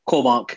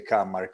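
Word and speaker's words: Kåmark: Cormark, is that the right way to pronounce it, Pontus? Yeah, it is. Yeah, it is actually. Yes. It's it should be O. Uh, it's an A with a circle, but uh, internationally they say Kamark or whatever.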